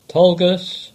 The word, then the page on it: Tolgus: English